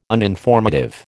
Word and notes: uninformative: American English

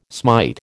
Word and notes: smite: American English

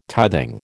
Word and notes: tithing: American English

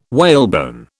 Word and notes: whalebone: British English

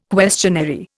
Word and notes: questionary: Indian

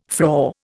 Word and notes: frore: Indian

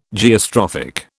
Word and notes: Geostrophic: British English

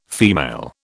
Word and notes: female: British English

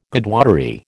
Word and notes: Godwottery: American English

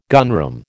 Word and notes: gunroom: American English

